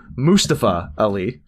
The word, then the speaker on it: Mustafa Ali: So the stable of retribution consisted of, well, obviously led by, let me pronounce this correctly, Mustafa Ali.